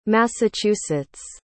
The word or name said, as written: Massachusetts